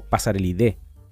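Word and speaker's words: Passeridae: El sufijo AE se pronuncia como E, por lo tanto quedaría como Pasarelidae. De este grupo familia se desprende un grupo mucho más específico que es el género.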